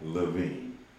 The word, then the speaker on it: LaVine: Zach LaVine.